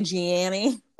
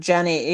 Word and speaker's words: Jeannie: Jean, yeah, I want to say Gianni, but it's going to be Jeannie. Right? Oh, Jenny Mai.